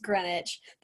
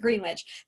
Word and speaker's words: Greenwich: And anytime somebody asks them where Greenwich is, because it's pronounced Greenwich, the tourists pronounce it Greenwich, they're going to run around screaming.